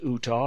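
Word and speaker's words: Eutaw: That spelling, by the way, E-U-T-A-W, I hope it's pronounced Utah, or I'll be hearing of it.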